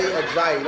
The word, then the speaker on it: Ajayi: How do you say his name? Jay Ajayi. Jay Ayoyo? – Jay Ajayi. Let's say it together. – Jay Ajayi. Like Ayayayi? – Like Guillermo?